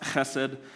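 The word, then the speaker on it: chesed (חסד): This Hebrew word, it's pronounced chesed,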